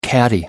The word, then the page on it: caddie: American English